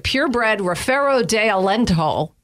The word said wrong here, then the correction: Alentejo: Oh, wait, Alentejo.